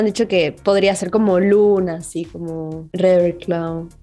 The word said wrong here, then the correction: Ravenclaw: ¿Cómo se pronuncia? Ravenclaw. – Ravenclaw. – No, Ravenclaw. Gracias, por ayudarme con la pronunciación.